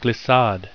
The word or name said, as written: glissade